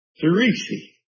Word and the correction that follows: Tishri: It's Tishri. – Huh? – Tishri. – Tishri. – Yeah. – Okay, Tishri.